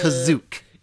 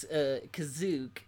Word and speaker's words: Kashyyyk: Technically it's Kazook. – You know, in the 1978 holiday special, they pronounce Kazook, which is the first appearance of Lumpy.